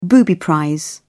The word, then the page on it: booby prize: British English